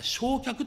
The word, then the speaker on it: 正客: ちなみにこれ正客と書いて焼却と読むんですが、本来は中心となるお客様、主賓を意味する言葉で、特に茶会などにおける最上位の客を指すそうです。焼却とは茶会の最上位のお客さんを指すそうです。